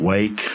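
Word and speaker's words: Rwake: It's a band from Little Rock called Wake. It's actually spelled with an R, R-W-A-K-E.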